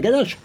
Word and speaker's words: Ganache: Il n'est pas venu encore sa cravate, hein, mais euh, presque Fabien de, de la ganache, euh, hein, on prononce comme ça, la ganache.